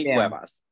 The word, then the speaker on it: Cuevas: Juni Cuevas.